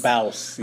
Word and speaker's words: Bawse: I tried to think of a word earlier today to describe her, just one word, and it would be a hashtag, Bouse. I think I'm saying that right. – Who? – Bouse. B-A-W-S-E. – I'm a Bouse. – Bouse. – Bouse. – Bouse, yeah.